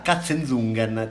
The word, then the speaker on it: Katzenzungen: Eh, questo è il nome e sicuramente i, gli amici tedeschi lo pronunciano così. Quindi a Katzenzungen c'è una vigna che cresce da 350 anni e siamo contenti che, meraviglia, che, che cresca, meraviglia.